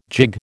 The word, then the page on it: jig: American English